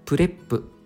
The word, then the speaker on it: PREP: ?PREP プレップと読むんですが、うん、 4 つの段落で構成しようというものですね。